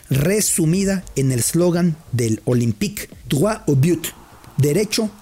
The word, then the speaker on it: Olympique: Por eso el nombre, Olympique, o por pronunciarlo muy galamente, espero que luego no me corrijan, Olympique.